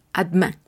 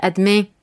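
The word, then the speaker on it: à deux mains: Entonces tengamos cuidado, prestemos atención y esta E que se encuentra al lado de la D la pronunciaremos muy tenue, adman. Ahora, es cierto que en Canadá la, pronunciación sería diferente, sonaría más como una E nasal y sería adme, adme.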